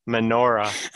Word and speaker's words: menorah: But you're also just saying the word minority, though. – Okay, I need to pronunciate it a little bit better. Menorah.